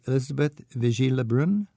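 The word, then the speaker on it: Élisabeth Vigée Le Brun: And one that I've gotten to know, because she's quite prolific as a portrait artist, is, forgive my pronunciation, but Elizabeth Vigée-Lebrun? – Yes.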